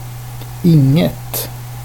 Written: nothing

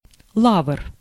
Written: laurel

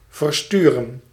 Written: schicken

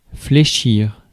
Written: fold

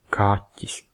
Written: cat

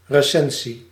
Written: Kritik

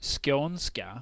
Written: Scania

